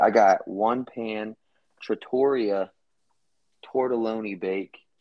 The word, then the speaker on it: tortelloni: It's spelled with an O, like ONI. – Oh, then that must be some difference. – Tortelloni.